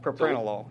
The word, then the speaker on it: Propranolol: And if there is a cure, or simply medication like, I'm not sure I can pronounce it, propapanol? – Propranolol, yeah.